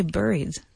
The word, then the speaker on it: buried: I would say buried. – Mm-mm. – Buried. – It's pronounced the same as strawberry.